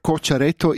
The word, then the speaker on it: Cocciaretto: Quello batti oggi a mesdì in la prima runda l'italiana Elisabetta Cocciaretto, lois de fer attenzium, scuccia la pronuncia, Cocciaretto in 3 sets con 6-3, 2-6 e 6-2.